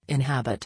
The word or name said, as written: inhabit